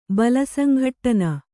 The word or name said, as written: ಬಲಸಂಘಟ್ಟನ